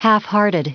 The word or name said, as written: halfhearted